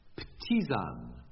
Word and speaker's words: ptisan: Ptisan. I'll spell it for you. P-T-I-S-A-N. – It's Greek, I would think, from the silent P, which is Greek, and or swimming vowels, again.